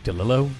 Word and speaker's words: DeLillo: How would you say that last name? – DeLillo. – DeLillo. That sounds right.